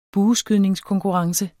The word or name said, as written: bueskydningskonkurrence